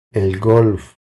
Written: El golf

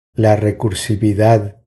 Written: La recursividad

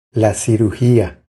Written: La cirugia

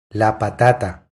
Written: La patata

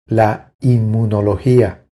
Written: La inmunologia

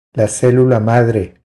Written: La celula madre

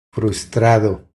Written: Frustrado

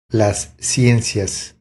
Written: Las ciencias

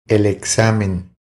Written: El examen

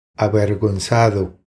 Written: Avergonzado

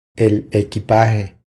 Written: El equipaje

